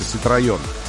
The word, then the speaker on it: Ситроен: Правильно произносится Ситроен.